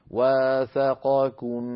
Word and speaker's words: وثاقكم: أيضا ننتبه إلى في الآية 7 إلى كلمة وميثاقه الذي وثاقكم، ثاقكم، التقى هنا في النطق قاف بعدها كاف، والقاف والكاف مخرجاهما قريبان من بعضهما، فعلينا أن نعطي القاف حقها من المخرج والتفخيم، والكاف حقها من المخرج والترقيق.